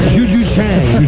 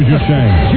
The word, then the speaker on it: Juju Chang: Bohemily Chan. I hope I said that right. – Hello. – Bohemily Chan? – Juju Chang. – Juju Chang.